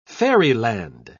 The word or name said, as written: fairyland